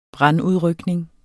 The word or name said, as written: brandudrykning